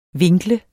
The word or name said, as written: vinkle